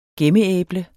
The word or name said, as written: gemmeæble